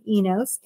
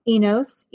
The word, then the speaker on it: Enos: Yeah, you can follow me on Facebook at Amy Enos, A-M-Y, Enos, E-N-O-S.